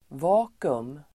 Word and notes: vakuum: [v'a:ku(u)m]

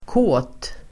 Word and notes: kåt: [kå:t]

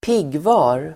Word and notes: piggvar: ²p'ig:va:r

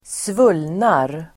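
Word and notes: svullnar: [²sv'ul:nar]

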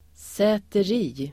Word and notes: säteri: [sä:ter'i:]

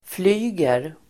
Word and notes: flyger: [fl'y:ger]

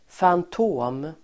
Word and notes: fantom: fant'å:m

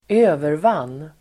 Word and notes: övervann: [²'ö:vervan:]

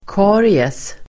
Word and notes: karies: [k'a:ries]

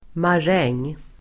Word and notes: maräng: [mar'eng:]

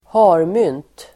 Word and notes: harmynt: [²h'a:rmyn:t]

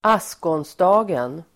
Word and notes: askonsdagen: [²'as:konsda:gen]

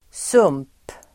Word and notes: sump: [sum:p]

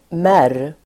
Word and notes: märr: [mär:]

